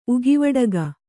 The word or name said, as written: ಉಗಿವಡಗ